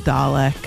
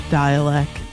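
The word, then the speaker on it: Dälek: You might pronounce it Dalek. It's pronounced Dialect. D A with an umlaut, L E K, New Jersey zone, from his new album Brilliance of a Falling Moon.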